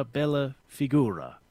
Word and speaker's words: bella figura: Another one I was intrigued by is fara bella figura. I guess that's presenting your best self. – Right, bella figura. Very good pronunciation there, Brian.